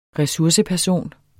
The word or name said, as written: resurseperson